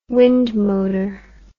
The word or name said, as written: wind motor